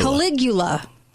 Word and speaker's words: Caligula: Caligula.